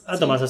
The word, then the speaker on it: Atomas: ¿Atomas? – Atomas, así como suena, como átomos pero con A.